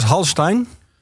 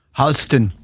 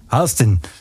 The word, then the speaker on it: Halstein: De hoofdpersoon, spreek je het gewoon uit als Halstein? Halsten. – Halsten. Is dan de officiële uitspraak.